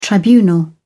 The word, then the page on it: tribunal: British English